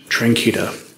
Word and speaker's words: Tranchida: I'm Dr. Vincent Tranquita. My name is spelled V-I-N-C-E-N-T. My last name is spelled T-R-A-N-C-H-I-D-A.